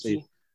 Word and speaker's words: Iki: Of Iki. – The problem is... – Am I pronouncing that right? I'm not sure. – Yes.